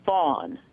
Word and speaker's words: Fawn: Brody is B-R-O-D-I-E, and it's Fawn. F-A-W-N, rhymes with dawn, Fawn M.